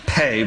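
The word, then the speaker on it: Pe: It is spelled P-E, but it is pronounced Pei.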